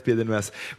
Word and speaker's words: Pédelmas: Marie-Arlette Pédelmas.